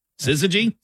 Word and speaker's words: syzygy: I will spell it for you. – Okay. – S-Y-Z-Y-G-Y. Syzygy? – Syzygy. – S-Y-Z-Y-G-Y? – Syzygy.